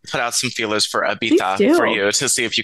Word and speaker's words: Ibiza: Guys, I'm coming to you live from Ibiza. That's how the locals say it. That's right, Ibiza.